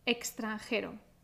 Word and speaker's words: extranjero: Si pronunciamos la X como una X, vamos a pronunciar de una manera más enfática. Extranjero, extranjero.